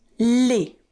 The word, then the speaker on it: les: And then you've got les. Les. Now, les is spelled L-E-S.